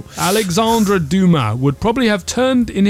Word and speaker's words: Dumas: It's spelled D-U-M-A-S, Dumas. But I think you don't pronounce the S because it's French.